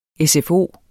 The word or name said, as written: SFO